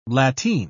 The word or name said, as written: lateen